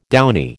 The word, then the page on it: downy: American English